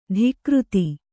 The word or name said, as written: ಧಿಕೃತಿ